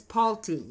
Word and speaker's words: Palti: So Paltai returned. – Now, I want to stop right there. I feel so bad for Palti. – It's Palti, yeah.